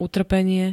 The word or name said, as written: utrpenie